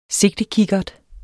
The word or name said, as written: sigtekikkert